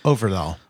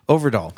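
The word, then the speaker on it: Øvredal: Oh, the director, Andre Overdahl. – Overdahl. – Overdahl. – Why is that so hard? Overdahl. – Because it's like, I don't know. Look at the O.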